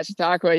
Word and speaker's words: Chautauqua: Chautauqua, right? Or if I'm saying it right, I don't even know if I'm saying it right. – Yeah, but man, dude, nailed it. Yeah, Chautauqua.